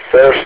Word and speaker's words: Serge: My name is Serge,